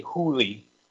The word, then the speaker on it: Hole: Oh, it's pronounced holy. Or holy. – That doesn't make it any better. – Is there an accent somewhere? – It's because the movie is based on a Norwegian book. Its name is Harry Hooley.